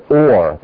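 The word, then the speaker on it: or (אור): So this is the word or. The last letter, resh, gives it the R sound. This gives it the O sound, and the Aleph is silent.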